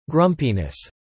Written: grumpiness

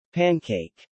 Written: pancake

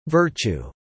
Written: virtue

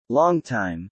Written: longtime

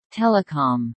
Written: telecom

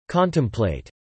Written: contemplate

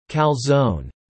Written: calzone

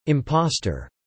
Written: impostor

